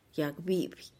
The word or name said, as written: yag bipih